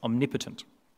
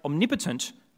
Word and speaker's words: omnipotent: Because God can do that, but he can also do all things because God is omnipotent. Word number 7. Now, we should remember from the time that Rowan and Sam came up on stage that is pronounced omnipotent, not omnipotent.